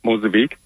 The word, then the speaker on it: Mozambique: Mozambique? – Yeah, Mozambique.